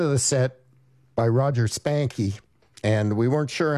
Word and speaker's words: Spanke: His name spelled S-P-A-N-K-E. – Well, we consulted the Oracle, Deke Dickerson, and he got right back to us. – And he said most people in Bakersfield pronounce the E, so there we are, Roger Spankey.